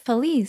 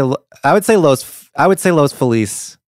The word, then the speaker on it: Los Feliz: How she pronounces this part of Los Angeles, L-O-S space F-E-L-I. But how do you say it? – Oh, sorry. – Oh, well, I've been called out by a Hispanic person for saying it wrong, and I forgot how they told me to say it. – No, that but is right, Feliz. – I would say Los Feliz.